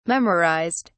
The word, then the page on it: memorized: British English